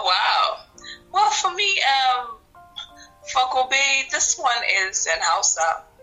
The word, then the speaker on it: Gobe: Is it Gobe or Gobe? G-O-B-E? – Gobe.